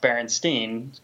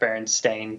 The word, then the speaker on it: Berenstain: It's not pronounced Berenstain. Quit saying that because it is, in fact, Berenstain Bears. Mm-hmm. So we even say it wrong. Not only do we remember it being spelled wrong, but we are still saying it wrong, even though we know how it's spelled now.